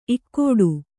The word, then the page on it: ಇಕ್ಕೋಡು: Kannada